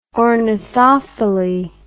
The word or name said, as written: ornithophily